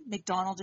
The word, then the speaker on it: McDonald: McDonald is spelled M-C-D-O-N-A-L-D.